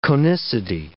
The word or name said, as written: conicity